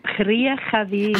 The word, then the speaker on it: Kriechenwil: Und jetzt darf ich Ihnen vorstellen, Kanton Bern zuhause in Kirchenwil. Habe ich das richtig gesagt? – Ja, Kriechenwil.